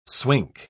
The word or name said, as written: swink